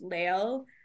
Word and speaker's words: Lael: I think it's pronounced Lael, L-A-E-L, Litke.